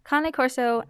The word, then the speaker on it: Cane Corso: So you wouldn't pronounce it Cane Corso as it is typed, or Cane.